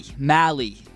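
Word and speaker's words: Mahle: Mally. – Rhymes with rally.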